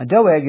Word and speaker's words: Doeg: Now, Doeg is memorable because his name kind of sounds like dog, only it's stretched out, Doeg, you know, dog. But it's obviously not spelled like dog, it's D-O-E-G.